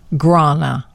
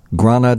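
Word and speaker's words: Gráinne: I'm going with this one, Grana. – It's G-R-A-I-N-N-E, Grana Duffy.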